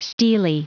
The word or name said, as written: stele